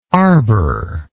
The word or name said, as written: arbour